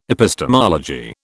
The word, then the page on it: epistemology: British English